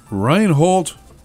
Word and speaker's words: Reinhold: But before we do, our quote this afternoon comes from Reinhold.